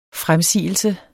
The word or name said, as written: FREMSIGELSE